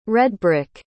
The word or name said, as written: redbrick